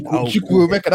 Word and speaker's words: Chukwuemeka: Chukwuemeka, that's what you call him.